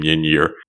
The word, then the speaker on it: Ynyr: At this point, Prince Colwyn is nursed back to health in a very short sequence by Yinyir, the old one. Can't even say his name, Yinyir. That's spelled Y-N-Y-R.